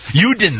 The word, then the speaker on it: Juden: Juden. How do you pronounce it in German? Juden, that's right, thank you.